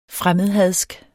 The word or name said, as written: fremmedhadsk